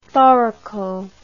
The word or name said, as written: thoraco